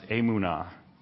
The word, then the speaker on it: emunah: Okay, there's this word that I'm going to butcher, but I believe it's pronounced emunah. Emunah.